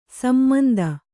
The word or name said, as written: ಸಮಂತು